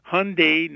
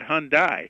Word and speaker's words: Hyundai: And by the way, we have a brand, and the brand is, please pronounce it correctly, Hyundai, not Hyundai.